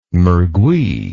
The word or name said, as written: mergui